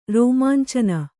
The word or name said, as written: ರೋಮಾಂಚನ